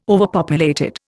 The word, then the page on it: overpopulated: Indian